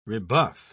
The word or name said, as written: rebuff